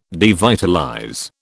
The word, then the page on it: devitalize: British English